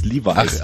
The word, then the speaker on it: Levi's: Levels, Levi's.